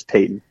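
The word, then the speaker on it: Paton: George Peyton, not Peyton Manning, whose name is, looks like George Patton, but it's Peyton is how it's pronounced.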